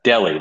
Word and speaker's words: Delhi: Yeah, it's Delhi, like as in deli meats.